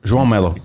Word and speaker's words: João Melo: João Melo. – Can you spell that for me, please? – J-O-A-O-M-E-L-O.